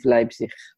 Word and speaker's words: Leipzig: Leipzig, how do you say that in English? – Leipzig. Well, that's how you say it in German.